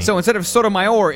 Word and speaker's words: Sotomayor: Because I always used to pronounce her name Sodomizer. – Mm-hmm. – But then I learned it was Sotomayor.